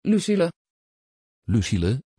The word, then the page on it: Lucille: Dutch